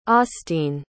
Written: austine

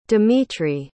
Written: dmitri

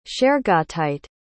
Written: shergottite

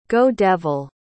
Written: go-devil

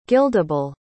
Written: guildable

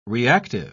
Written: reactive

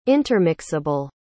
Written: intermixable

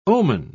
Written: omen

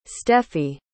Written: steffie

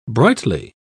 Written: brightly

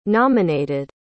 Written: nominated

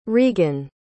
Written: regan

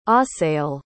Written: Asael

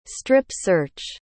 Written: strip-search

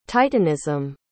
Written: titanism

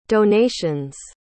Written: donations

